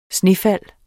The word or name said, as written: snefald